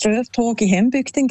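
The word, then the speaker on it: Strövtåg i hembygden: Ich finde, du hast die Aussprache super hergebracht. Das Lied heisst Ströftag in Hembügden, genau.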